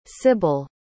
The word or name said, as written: sibil